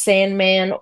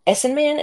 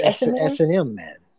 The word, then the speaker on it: S&Man: It could be pronounced Sandman or S&Man. Is that right? – S&M Man. – Yeah. S&M Man.